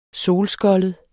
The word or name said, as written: solskoldet